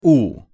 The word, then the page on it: у: Russian